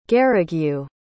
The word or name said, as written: garigue